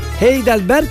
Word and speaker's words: Heidelberg: Heidelberg yazılır, Heidelberg okunur. G yok onun sonunda. Niye okuyoruz G ile onu bitiriyoruz o zaman?